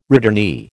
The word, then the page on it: returnee: American English